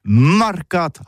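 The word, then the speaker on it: marcats: Mais en gascogne toulousaine, c'est prononcé sur les marcates, avec un A.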